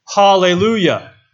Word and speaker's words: hallelujah: But the phrase, praise the Lord, is actually pronounced in Hebrew, hallelujah.